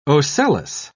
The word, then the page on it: ocellus: British English